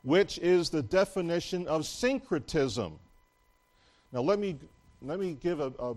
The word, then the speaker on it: syncretism: You pronounce it syncretism, S-Y-N-C-R-E-T-I-S-M.